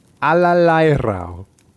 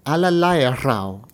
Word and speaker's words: Allerleirauh: Allerleirau. Allerleirau. – Allerleirau.